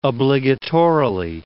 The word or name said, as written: obligatorily